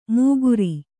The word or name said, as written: ಮೂಗುರಿ